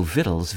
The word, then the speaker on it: victuals: It's also the root of viand, V-I-A-N-D, another English word referring to food. There's also victuals, V-I-T-T-L-E-S, a word that sounds like it might be an alteration of the plural victuals, V-I-C-T-U-A-L-S. Both are pronounced the same, but which is actually just an earlier development of the Middle English vitaille that was served before victual.